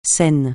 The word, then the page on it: Seine: francais